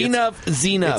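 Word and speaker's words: Enuff Z'Nuff: Say it right. – Enough Zenuff. – It's Enough Zenuff. – Enough Zenuff. – Kind of like E-Z-E.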